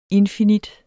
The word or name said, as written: infinit